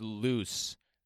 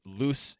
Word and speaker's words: Luz: I watched Loose. They pronounce it Loose, L-U-Z. – Okay. – They pronounce it Loose and not Loose, like the Spanish way in this movie.